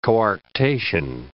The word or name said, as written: coarctation